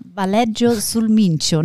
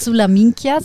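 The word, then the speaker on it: Valeggio sul Mincio: Valleggio sul Mincio, nicht sulla Minchia, sondern sul Mincio.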